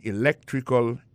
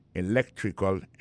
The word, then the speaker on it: electrical: I majored in electrical engineering. Electrical engineering, ça veut dire accent tombé dans electrical là, sur le deuxième syllabe là, electrical engineering. C'est sur le deuxième syllabe là, elect là, plus appuyé.